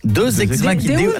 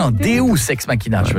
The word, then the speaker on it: Deus ex machina: Non, Deus ex machina. – Ah, parce que deux, j'aurais dit que c'était deux ex que t'appelles machine. Tu vois. – Non, ça se prononce Deus ex machina.